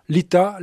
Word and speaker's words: Litha: Cela s'écrit L-I-T-H-A, Lita, l'alliance de trois musiciennes du pays de Redon et du Poitou.